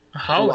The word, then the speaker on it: Huawei: Huawei que fala da, da China lá. A... Ah. Não sei a pronúncia, mas é essa empresa assim que tu falou. – Huawei.